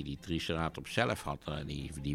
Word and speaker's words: Triceratops: Nou, dat is dus Triceratops. maar dat is dan de Engelse klemtoon.